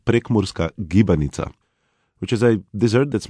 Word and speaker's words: Prekmurska gibanica: Oh, Prekmurska gibanica.